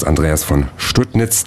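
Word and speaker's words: Andreas von Studnitz: Und der Intendant des Theaters, Andreas von Stuttnitz, der sich auf den mühevollen Weg durch den Baustellendschungel um sein Haus gemacht hat. – Name und auch er, richtig ausgesprochen, genau.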